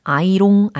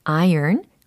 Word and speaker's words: iron: 네, 특히 어, 다림질하다라고 할 때 iron이라고 발음을 해야 되잖아요. I R O N 아이롱 아닙니다. iron 거기에 ing가 또 붙었으니까 ironing 이렇게 발음을 처리해 주셔야 되는 부분이었습니다.